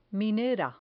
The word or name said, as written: minera